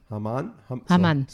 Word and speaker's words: Haman: Or how do you say it? I'm sure you're going to correct me. – We say Ahasuerus and... – Ahasuerus is Ahasuerus. – And Haman. – Haman. So Haman, yeah.